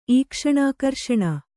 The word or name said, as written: ಈಕ್ಷಣಾಕರ್ಷಣ